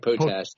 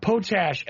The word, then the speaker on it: Potash: Now, that leads to some logical questions that John Potash, not sure how to pronounce it properly, Potash. But either way. – I like Potash. It's easiest. It's best. – Potash, and that's the way it looks, actually, P-O-T-A-S-H.